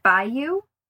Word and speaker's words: byuu: He goes by the handle, I think you would pronounce it Byuu. It's B-Y-U-U.